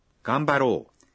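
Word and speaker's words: Gambaro (頑張ろう): Ele se torna Gambaro. Vamos nos esforçar ou eu vou dar o meu melhor. Mas atenção à pronúncia. O correto é Gambaro mas se escreve ga-m-ba-ro-u.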